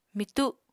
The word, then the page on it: mitu: Apatani